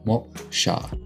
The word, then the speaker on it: moksha: I think I said that right. Moksha. M-O-K-S-H-A. Moksha. Hopefully that's correct.